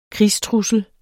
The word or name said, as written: krigstrussel